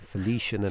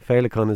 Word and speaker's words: Féileacán: They were calling it Felician and everything. Féileacán is obviously the word for butterfly, and and that's what we see in Irish mythology.